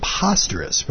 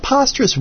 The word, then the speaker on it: Posterous: This was inspired by the fact that posterous, which supposedly truly is pronounced posterous and not posterous, even though it should be posterous because you're posting things, but apparently they pronounced it posterous as in preposterous.